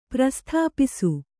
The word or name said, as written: ಪ್ರಸ್ಥಾಪಿಸು